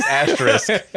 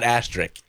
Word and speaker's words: asterisk: I love how you vocalize asterisk and you pronounce it correctly. It's not asterisk. It's asterisk.